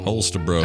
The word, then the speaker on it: Holstebro: And you're going to be playing for Nuana from Holstebro, Denmark. Maybe Denmark. – If you break it down into smaller chunks, it'll sound right. – That's Holstebro. – Holstebro, Denmark. Yo. Holstebro.